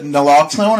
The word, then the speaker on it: naloxone: They came and they were able to administer, is it naloxone? Am I saying that right? – I think, yes.